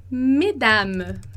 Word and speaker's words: mesdames: E aí, como eu falei lá, o S vai fazer abrir a pronúncia, né? Então, a gente vai falar mesdames, como a gente falou mes parents, mesdames, mesdames, que é senhoras.